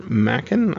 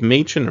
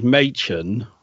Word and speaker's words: Machen: And we're going to talk about Out of the Earth, a short story by Arthur Machen, I want to say, but it's not. It's Machen, right? – It's supposedly the proper pronunciation is Machen, but everyone says Arthur Machen.